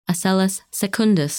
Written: Asellus Secundus

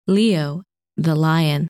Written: Leo

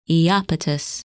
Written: Iapetus

